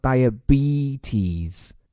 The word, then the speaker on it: diabetes: 首先，这个词的发音 diet 没问题，后面这个 b e t e s 两个一字母发的都是 e，所以叫 diabetes。乍听之下啊，带点鼻涕吃，口味有点重。这个词为什么表示糖尿病呢？其实，在医学上，糖尿病说全了，它是两个词构成的，叫 diabetes mellitus。